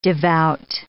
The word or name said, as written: devout